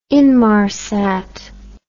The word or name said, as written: Inmarsat